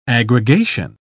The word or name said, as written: aggregation